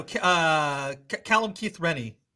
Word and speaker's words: Callum Keith Rennie: Because, you know, Callum Keith Rennie. Did I say his name right? – Yeah.